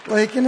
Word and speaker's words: Lacon: In other words, how do you pronounce LACON as in what? Oh, Lacon. LACON, Lacon. A good restaurant up there. It's so Twin Cities of Sparlin. Sparlin and Lacon. – So as in bacon, right? – Lacon as in bacon.